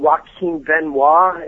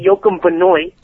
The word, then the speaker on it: Joaquin Benoit: I mean, what they've got right now in Detroit, I mean, you've got Valverde dealing with a wrist issue, and Joaquin Benoit, if I'm saying his name correctly. – Yeah, you're doing it. You're doing it. – I don't want to say Joachim Benoit.